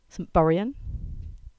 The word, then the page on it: Saint Buryan: English